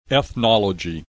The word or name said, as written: ethnology